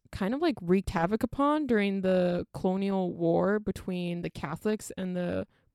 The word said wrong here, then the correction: Cologne War: Cologne War.